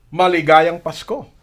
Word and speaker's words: Maligayang Pasko: Maligayang Pasko.